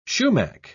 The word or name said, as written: sumac